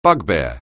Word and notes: bugbear: anglais

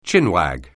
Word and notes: chinwag: anglais

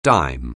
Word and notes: dime: anglais

